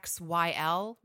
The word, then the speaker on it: PanOxyl: The one thing about Panoxyl, so it's Panoxyl, right? – Yes.